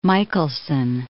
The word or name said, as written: Michelson